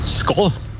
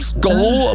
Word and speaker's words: Skål: Skål. – Wow. Richtig? – Echt? – Ja. – Oh. – Du hast sogar richtig betont. – Oh, wird Skal geschrieben, Skol, aber ausgesprochen.